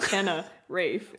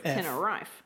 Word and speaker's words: Tenerife: It's not a reef. It's not Tenner Reef. – It's... Well, it's not reef. It's spelt T-E-N-E-R-I-F-E. – Not Tenerife. – Tenerife.